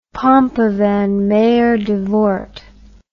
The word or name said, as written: Pompe van Meerdervoort